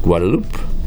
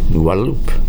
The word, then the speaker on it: Guadalupe: It's pronounced Guadalupe. Guadalupe.